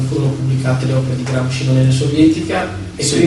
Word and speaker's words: Gramsci: Gramsci, noi pronunciavamo Gramsci o Gramsci alla francese, e lui diceva: meglio mettere Gramov alla russa.